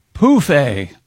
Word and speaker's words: pouffe: Anyway, Europeans of a certain age use the French word pronounced pouffe for an ottoman, phonetically spelled P-O-O-F hyphen A.